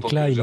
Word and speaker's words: Klein: Ouais, et Pascal fait remarquer dans la chatroom que Klein, ça se prononce pas comme ça en allemand. – Ah, je prononce à la française. Hein. – Ouais, mais tout le monde prononce ça comme ça. – Tout le monde. Quelle c'est perspective mal. Franco-française. – Non, ce serait Klein en allemand.